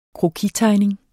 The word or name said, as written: croquistegning